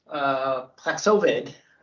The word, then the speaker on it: Paxlovid: Paxlovid.